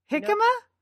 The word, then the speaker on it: jicama: Who knew that hemica, am I saying that right? Hemica. How do you say that? – Jicama? – Jicama. I say, loser.